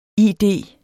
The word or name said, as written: id